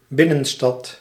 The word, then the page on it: центр: [t͡sɛntr]